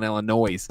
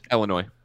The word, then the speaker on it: Illinois: I don't believe you pronounced the S. – That's true, I know. – Okay. In what word? – Illinois. – Illinois. – Oh, I said Illinois.